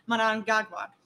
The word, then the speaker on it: Mnangagwa: Now, the way their president, let me see if I pronounce it right, Emerson Mnangagwa, Mnangagwa, that's what it is, Mnangagwa.